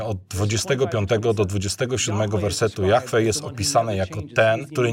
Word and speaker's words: Jahwe: Wiecie, kim jest Jahwe? Jest to imię, które niewłaściwie wymawiamy jako Jehowa.